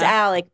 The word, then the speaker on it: Alex: So his name is Alec, but it's spelled with an X. – Yeah. Alex Murdaugh versus like Hailey Bieber and Selena Gomez.